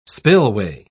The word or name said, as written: spillway